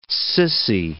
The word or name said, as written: sissy